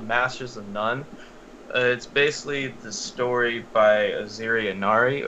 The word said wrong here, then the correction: Aziz Ansari: Let me not butcher his name. Hang on. Real quick. Aziz Ansari.